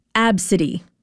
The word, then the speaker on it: Abcde: For example, the name, I'm going to spell it for you, A-B-C-D-E, pronounced Absidy.